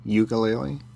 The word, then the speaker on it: ukulele: Ukulele? – It's pronounced ukulele by the ukulele community, which is true, by the way.